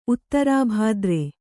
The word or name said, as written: ಉತ್ತರಾಭಾದ್ರೆ